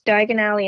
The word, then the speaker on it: Diagon Alley: And you really liked, like, I think it's called, is it Diagon Alley? Is that how you say it? – Yes, Diagon Alley and Hogsmeade.